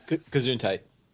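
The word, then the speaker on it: Tsojcanth: Yes, Lost Caverns of Tajkanth. Yeah. – Yes, that one. – Kazuntite. – Exactly. Yes. – The T is silent.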